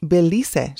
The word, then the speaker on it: Belize: It Belize, she would say, insisting on the Spanish pronunciation of our native country.